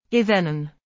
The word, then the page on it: izin: arabic